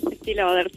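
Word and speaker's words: Estée Lauder: Ahora estamos de la organización Estelauder. ¿Lo pronuncié bien? – Estelauder, sí.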